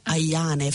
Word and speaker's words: Ayane: O dall'Africa, però cioè, parlando di ballate, scusate, c'è Malika Ayane, o Ayane forse si pronuncia veramente in italiano. – Pronunciamo in italiano, dai.